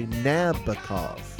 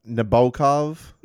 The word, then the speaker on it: Nabokov: But are you talking about Nabokov? – No, I know Nabokov. – Well, it's the emphasis is supposed to be on the B-O.